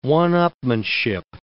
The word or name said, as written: one-upmanship